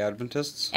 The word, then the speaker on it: Adventists: It was kind of like a a hippier version of Seventh Day Advent. how do you say it? – Seventh Day Adventists. – Adventists. Okay.